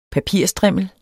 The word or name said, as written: PAPIRSTRIMMEL